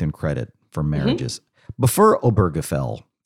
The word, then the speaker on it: Obergefell: And I'll tell you as an aside, I don't know if, is it Obergefell or Obergefell? I've heard it several different ways. And at some point I'm just going to say Bob, because I have no idea how to pronounce it. – Yeah. So I think that's a valid question. I pronounce it Obergefell, but I've also heard it said many different ways. So I'm just going to continue to say Obergefell.